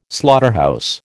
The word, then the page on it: slaughterhouse: American English